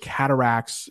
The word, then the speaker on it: cataracts: Cataracts. That's how that is pronounced. May not look like it.